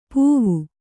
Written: ಪೂವು

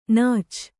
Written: ನಾಚ್

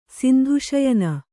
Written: ಸಿಂಧುಶಯನ

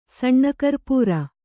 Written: ಸಣ್ಣಕರ್ಪೂರ

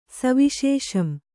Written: ಸವಿಶೇಷಂ

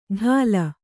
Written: ಢಾಲ

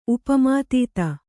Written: ಉಪಮಾತೀತ

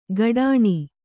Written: ಕಡಾಣಿ

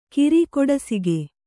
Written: ಕಿರಿಕೊಡಸಿಗೆ

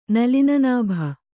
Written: ನಲಿನನಾಭ

